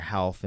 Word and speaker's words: Ralph: Um, uh, so it's like his, I think it's his brother Half, and it's spelt Ralph.